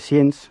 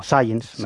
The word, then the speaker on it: Science: Esta es una historia que además ha dado a conocer una de las revistas científicas más importantes, Science, ¿no? Y, o Science, mejor dicho, que luego sí, se meten decir, conmigo por mi pronunciación.